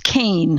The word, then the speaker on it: Kean: And your second name is Keen, K-E-A-N. Am I saying that right? – Well, it's actually pronounced Kane, Howard. Kane. It's a Scottish name, and I've lived with it all my life, and it's always, you know, nobody would ever know from reading it that it was pronounced Kane, like C-A-N-E, but that's how it's pronounced, so I'm stuck with that.